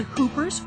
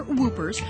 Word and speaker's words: whoopers: Whether you pronounce it hoopers or whoopers, the fact remains, once on the verge of extinction, the whooping crane is back.